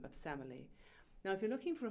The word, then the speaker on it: Semele: It's been sung at many a wedding, and that's Where'er You Walk from Handel's Semele, or Semele, I should say. In the English, it's pronounced Semele,